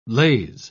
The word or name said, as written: laze